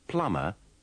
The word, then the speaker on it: plumber: In these sentences, you will have to be careful about the pronunciation of plumber and plumbing. Remember, the B is silent in both words.